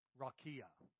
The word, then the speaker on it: raqia: The word is ra-ki-a. You could spell it R-A-Q-I-A. Rakia. Rakia.